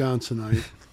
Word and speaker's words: Wisconsinite: a fellow Iowan and a fellow Wisconsin, Wisconsinian. Is that how you say it? – Wisconsinite. – A fellow Scotty. Wisconsinite.